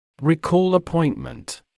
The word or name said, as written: recall appointment